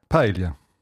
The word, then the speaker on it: Paella: Paella.